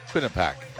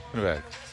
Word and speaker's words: Quinnipiac: I already asked if I said it right. – And you did, so you could try it again. – Quinnipiac. – That's it. And if you say it real fast, sounds even better.